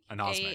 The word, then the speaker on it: anosmic: Ansomic. Is how you pronounce Anosmic. – It, I think.